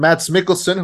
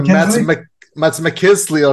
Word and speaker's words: Mikkelsen: And I wanted to mention Mads Mikkelsen, who plays the villain, because I wanted to make fun of the fact that you said his name wrong on the video review. You called him Mads McKinsey or something.